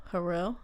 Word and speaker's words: Har’el: Har’el, that's how you pronounce the director.